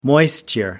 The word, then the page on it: moisture: American English